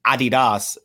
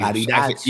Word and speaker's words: Adidas: Even though Ye significantly benefited Adidas is how you Adidas. – I think that's how you're actually supposed to pronounce it, but.